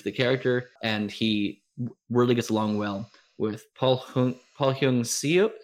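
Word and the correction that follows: Paul Sun-Hyung Lee: I'm looking at Paul Sun Hyung Lee.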